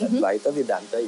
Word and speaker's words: Advaita Vedanta: And yes, by the way, you said it right. It's Advaita Vedanta. You're correct.